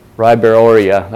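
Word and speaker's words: Ribeiroia: I've heard this pronounced several different ways. Ribeirorhia. Yeah. There you go. Ribeirorhia.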